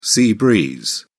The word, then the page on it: sea breeze: British English